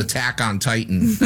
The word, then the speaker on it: Attack on Titan: I'm sorry. You mispronounced that. It's called Attack on Titan.